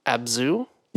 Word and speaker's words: Abzu: And I picked up, I'm going to pronounce this wrong. I know it. Abzu? – Yeah, I'm pretty sure that's how you pronounce it. – It's A-B-Z-U.